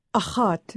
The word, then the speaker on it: Agathe: Agathe. It's a scraping sound in the deep guttural areas of the throat.